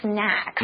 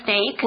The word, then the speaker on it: snack: Okay, 所以到底要做什么事情？我们先看后面好了。所以我给 Lucy 很多的水和点心，我也和她玩球，感觉好像是你要怎么样？哦，要当心，要留意这样的意思。So, keep one's eye on somebody or something, 就是留意什么什么。And we also saw the word snack. Snacks, okay? Remember the pronunciation is not...Snake, okay? – No. – No, you don't want to eat a snake.